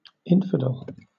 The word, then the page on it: infidel: British English